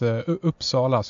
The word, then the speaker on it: Uppsala: The word you're looking for is Uppsala, so you're very close to it, by the way.